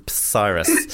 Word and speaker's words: Psyrus: We were called Cyrus with a silent P. At the front, it was Psyrus.